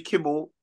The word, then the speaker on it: Kimmel: Jimmy Kimmel.